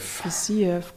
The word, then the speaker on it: Fiziev: Fiziev, gut ausgesprochen, mein Freund.